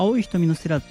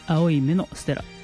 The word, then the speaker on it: 青い瞳のステラ: だから青い瞳のステラって思ってる方多いと思うんですけど、青い目のステラと読むようです。